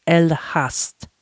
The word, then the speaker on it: Elegast: The cidery, it has a Dutch name, of course, but it's spelled Elegast. And if you heard me talk or speak kind of like butcher up Dutch in the past, the G is often pronounced as H. So it's the N, E is an A. So it's Elhast Distillery.